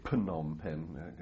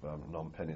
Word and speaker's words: Phnom Penh: People say Phnom Penh. That's how it's spelled. But I think we used to call it Phnom Penh.